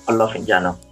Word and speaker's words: Olofinjana: Israel Olowole Olofinjana.